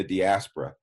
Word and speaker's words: diaspora: So in your book, you used a phrase that I was not familiar with, and I might mispronounce it. Diaspora, is that proper? – Oh, diaspora.